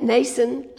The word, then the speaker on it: Mnason: And so we've got a big group of guys that are coming, assortment of people, and they're going to stay at Mnason, I understand that M is not pronounced, of Cyprus, a disciple of long standing with whom we are to lodge.